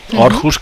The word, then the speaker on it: Aarhus: Hi ha una altra ciutat gran, és Aarhus, que s'escriu Aarhus amb la O, una petita O a sobre de la A, que quan és així s'ha de pronunciar com si fos una O, Aarhus,